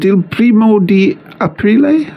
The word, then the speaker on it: Il Primo di Aprile: Italians call it Il Primo di Aprile. Is that how you say it? – Indeed, yes. Very good.